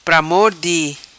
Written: Pramor di

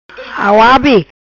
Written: Auabe

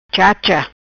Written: Chacha

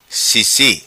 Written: Sisi

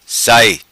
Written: Sai

